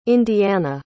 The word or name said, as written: indiana